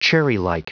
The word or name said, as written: cherrylike